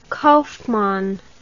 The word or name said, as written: kauffmann